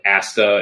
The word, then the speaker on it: Asta: I remember nothing on the level of Asta and you said it right.